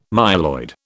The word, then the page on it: myeloid: British English